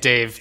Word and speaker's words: DA7E: I'm Dave Gonzalez. My first name is DA7E. That's how I spell it. It's also how I say it, Dave. The 7 sounds like a V.